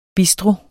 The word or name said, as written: bistro